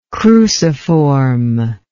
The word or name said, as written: cruciform